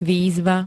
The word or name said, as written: výzva